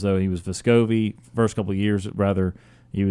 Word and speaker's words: Vescovi: It's just a difficulty how to pronounce it sometimes. Vescovi. – Yeah, it is officially Vescovi.